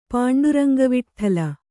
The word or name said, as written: ಪಾಂಡುರಂಗವಿಟ್ಠಲ